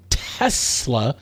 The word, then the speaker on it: Tesla: She confirmed that it is definitely Tesla with an S rather than a Z sound.